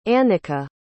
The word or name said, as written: annikka